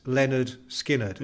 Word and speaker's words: Lynyrd Skynyrd: And that's why the band is pronounced Lynyrd Skynyrd. Yeah. And in fact, the title of their first album, pronounced Lynyrd Skynyrd, yeah. – Known forever after as Lynyrd Skynyrd.